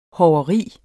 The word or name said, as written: hoveri